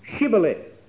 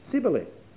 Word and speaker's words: Shibboleth: They said unto him, say now, Shibboleth. And he said, Sibboleth. For he could not frame to pronounce it right.